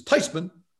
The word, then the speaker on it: Theismann: She says, the correct pronunciation is Theismann.